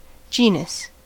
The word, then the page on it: genus: American English